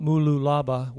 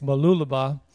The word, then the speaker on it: Mooloolaba: I'm glad I said that right. Mooloolaba. It's not Mooloolaba, which I thought it was, but it's Mooloolaba.